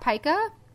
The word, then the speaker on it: pica: So instead, he had read a story about someone with pica, which it is pronounced pica. It's P-I-C-A.